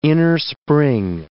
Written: innerspring

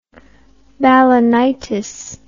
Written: balanitis

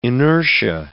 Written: inertia

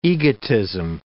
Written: egotism

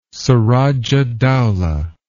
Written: surajahdowlah

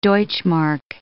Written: D-mark

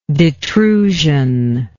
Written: detrusion

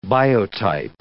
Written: biotype

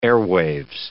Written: airwaves

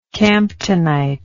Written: camptonite

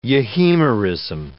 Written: euhemerism

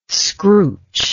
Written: scrooch

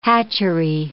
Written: hatchery

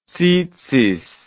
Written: tzitzith

